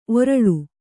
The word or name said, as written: ಒರಲು